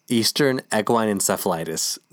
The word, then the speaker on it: Eastern Equine Encephalitis: That's how you pronounce it, by the way. Eastern Equine Encephalitis. Not whatever the fuck you said in the beginning.